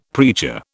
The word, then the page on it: preacher: British English